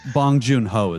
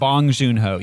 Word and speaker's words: Bong Joon-ho: Bong Joon-ho is his name. – Bong Joon-ho, yes.